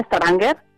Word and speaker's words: Stavanger: Pues mira, se llama Stavanger, es la cuarta ciudad más importante en Noruega y bueno, pues la importancia que tiene sobre todo relacionada con el petróleo.